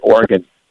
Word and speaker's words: Oregon: We say Oregon. – Yeah. – You know, like you're playing the organ. It's the same thing. It's not three syllables.